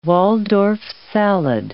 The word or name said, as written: Waldorf salad